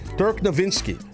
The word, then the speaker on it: Dirk Nowitzki: Dirk Nowitzki. That's the proper way to say his name.